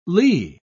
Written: lee

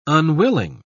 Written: unwilling

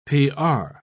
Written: pr

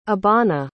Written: Abana

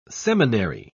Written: seminary

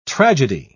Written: tragedy

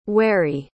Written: wharry

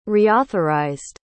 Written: reauthorized

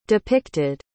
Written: depicted

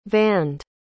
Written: vaned

